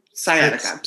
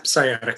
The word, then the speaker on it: sciatica: One hiker, Yuri Yudin, Yudin left early in the trip on January 28th due to a flare up of sciatica. Am I saying that right? – Sciatica. sciatica.